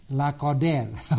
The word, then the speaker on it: Lacordaire: By the way, a French preacher by the name of Lacordaire, I'm not sure if I pronounced his name correctly. It's, let me spell it, L-A-C-O-R-D-A-I-R-E. How would you say that? Lacordaire. Okay, thank you. Lacordaire.